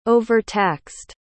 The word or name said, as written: overtaxed